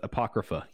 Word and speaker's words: apocrypha: But is this pronounced apocrypha? Thank you. – Yep, because that's a hard word to say. – It is. It's spelled a little strange, but apocrypha.